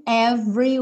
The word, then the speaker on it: every: Lucky we're in love in every way. Every. Lembra que esse E aí do meio tá pintadinho de cinza, então não vai ser pronunciado.